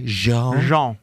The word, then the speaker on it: Jean: We can go with the soft J. Jean.